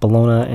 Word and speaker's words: bologna: And some My Kid Banana with Bologna and Chips. I think it's Bologna, but it's spelled Bologna, because that's how English is like.